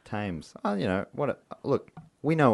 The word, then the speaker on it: Thames: I'm going to go drown myself in the Thames. I fucked up saying it wrong. – That's what I'm... Oh. – Thames. God damn it. – You got it half right.